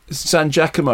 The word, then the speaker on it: San Giacomo: So how do you pronounce her name again? Who's in Sex Lies? I can never pronounce it. – San Giacomo. Laura San Giacomo.